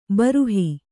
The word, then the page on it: ಭೂರುಹ: Kannada